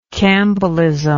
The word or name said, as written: campbellism